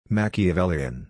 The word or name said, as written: Machiavellian